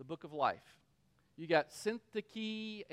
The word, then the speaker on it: Syntyche: I plead with Euodia and I plead with Syntyche. That's how you say it.